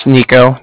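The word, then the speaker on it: Niko: Like I said, my name's Nico. That's N-I-K-O.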